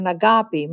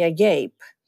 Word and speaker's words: Agapi: And it's spelled with an E, but I spell it with an I because when I studied in London and I spell it with an E, they used to call me Agape.